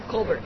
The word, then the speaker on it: Colbert: Is it Colbert or Colbert? – Colbert. – Okay. Only Stephen gets the Colbert.